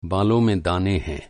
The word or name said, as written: दाना